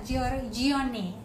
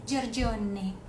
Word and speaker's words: Giorgione: Ahí está, esta Dresden de Venus que fue pintada en 1510 al 11, pero este fue por el que no puedo pronunciar, Miren, ahí está, Giorgione, Giorgione, con el acento italiano.